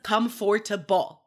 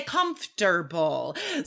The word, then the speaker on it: comfortable: Um comfortable Guys, nobody says "come for to ball." No. – Right. We just, that middle syllable just disappears. We say comfortable.